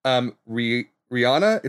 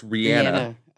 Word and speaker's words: Rihanna: Rihanna. It's not Rihanna. It's Rihanna. – Okay. – That's how it's actually pronounced.